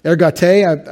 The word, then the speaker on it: ergate: Ergate, I transliterate the hey, because the eta, because that's how you write an eta in caps in Greek. But it's E-R-G-A-T-E, long E is the eta. Ergate.